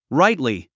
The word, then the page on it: rightly: ráitli; ライトリィ